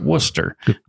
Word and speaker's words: Worcester: So, but the people of that fine town pronounce it Worcester. Worcester. – If you're saying it like rooster, you're doing it wrong.